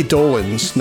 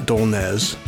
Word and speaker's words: Dolenz: It's Mickey Dolenz, not Dolenz.